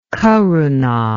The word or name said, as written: karuna